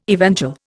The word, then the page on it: evangel: Indian